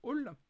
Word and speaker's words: Owlle: Je crois que ça se prononce bien Hall, c'est une artiste française de, de souvenir O-W-L-L-E.